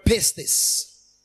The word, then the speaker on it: pistis: Faith is from the Greek word pistis. Pistis. Or pistis. P-I-S-T-I-S.